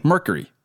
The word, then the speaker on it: Mercury: Because you say mirror. – I just did it. I just said it right. – Say it again. – Mercury. – One more time. – Mercury. – Okay. A little bit more cure-y? – Uh-huh. – Say cure-y. – Mercury.